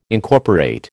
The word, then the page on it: Incorporate: American English